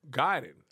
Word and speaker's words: Gaiden: Gaiden. – Oh, it was Ninja Gaiden? – Pronounced Gaiden.